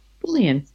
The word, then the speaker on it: Boolean: Boolean.